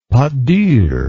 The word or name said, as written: pate dure